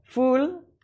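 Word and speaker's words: ful: Fal with hummus. – I've been, my pronunciation of this is all wrong. Fool with hummus.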